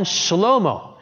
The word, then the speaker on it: Shlomo: Again, it's not obvious to an English reader, but a Hebrew person would pronounce Solomon Shlomo.